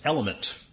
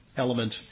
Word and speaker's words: LMNT: All right, there's a little bit of a controversy going on in nutrition land, and it's about a popular electrolyte supplement called LMNT, pronounced element. It's kind of clever, LMNT, element.